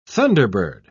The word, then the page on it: thunderbird: British English